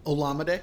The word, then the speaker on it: Olamide: How do you pronounce Zacchaeus' first name? – Olamide. – Olamide Zacchaeus is open in the mid-range, and Cole Kmet is open in the short range if you needed him.